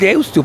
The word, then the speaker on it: Deusto: Es Deusto, porque se escribe con U final.